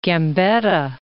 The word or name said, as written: Gambetta